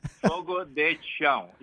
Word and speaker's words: Fogo de Chão: Fogo de Chão. If you say Fogo de Chão, that's good enough.